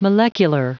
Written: molecular

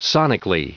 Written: sonically

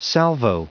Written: salvo

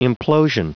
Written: implosion

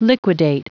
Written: liquidate

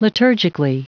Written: liturgically

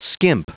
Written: skimp

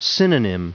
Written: synonym

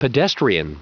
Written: pedestrian

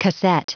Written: casette

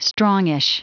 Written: strongish

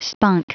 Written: spunk

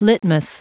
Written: litmus

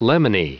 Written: lemony

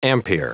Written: ampere

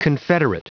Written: confederate

